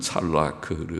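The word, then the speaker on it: 찰라크 (צלח): 제가 간혹 좀 웃음의 소리로, 우습다기보다 우리가 학교 다닐 때 찰라크라는 히브리어 단어를 외우면서 찰라크는 형통한, 우리말에 아주 잘 나간다, 잘 나간다고 말 발음이 비슷해서 찰라크, 찰라크 그렇게 외우는데 찰라크, 잘 된다, 혹은 형통한다, 성공한다 이런 뜻입니다.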